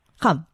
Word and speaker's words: Cham: Sie ruft uns aus dem Landkreis Cham, Cham an. Wie spricht man es richtig ähm, aus? Ramm. Okay, um, Sie, Frau Beidner.